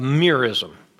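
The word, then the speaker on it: merism: In the ancient Near East, they had a figure of speech called a mirism. A mirism. Anybody, that won't be in your notes. It's spelled M-E-R-I-S-M, a mirism.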